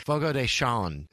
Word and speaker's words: Fogo de Chão: Oh my God, I said it wrong. Fogo de Chão is how you say it.